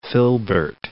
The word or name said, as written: filbert